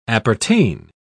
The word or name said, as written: appertain